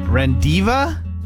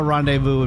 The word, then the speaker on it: rendezvous: R-E-N-D-E-Z-V-O-U-S. R-E-N-D-E-Z-V-O-U-S. – Rendiva? Divu? Rendezvous. Used his brain.